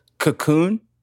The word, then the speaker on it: Cocoon: What was the first word you said? – Cocoon. – Oh, I just heard coom. – The first, first syllable there was eaten.